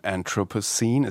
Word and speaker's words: Anthropocene: Talk about Kraftwerk, there is um, there is this track um, Anthropocene, is that how you say it in English?